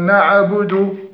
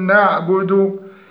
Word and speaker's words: نعبد: فمن الذين يقولون نعبد، وهذا خطأ، فنقول نعبد. إياك نعبد، إياك نعبد، وإياك نستعين. في نستعين من الأخطاء كسر الباء كسر الياء نستعين، زيادة زمن النطق بالضمة التي على الدال حتى تصبح واوا، هذا في نعبد، فنقول نعبد لا نقول نعبد.